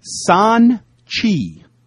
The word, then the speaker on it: Xanchy: Next up, subject heading, Michael Felger's comments on Randy Moss, and this is from San Chi, which is spelled X-A-N-C-H-Y. Long-time listener, first-time email. – Thanks, Joe Castiglione. – That's Adam Hizdu, H-Y-Z-D-U.